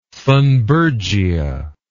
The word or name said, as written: thunbergia